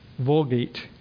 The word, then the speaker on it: Vulgate: Vulgate. – Vulgate.